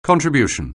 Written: contribution

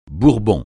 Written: bourbon

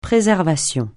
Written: préservation

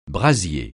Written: brasier

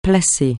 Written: placer

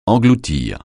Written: engloutir

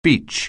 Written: beach

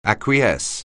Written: acquiesce

